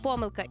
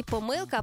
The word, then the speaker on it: помилка: і тепер «помилка» і «помилка» абсолютно рівноправні варіанти. Так само у слові «байдуже» і «байдуже», і у слові «фейсбук» чи «фейсбук». Наголос іде на два, подвійний наголос на два різні склади.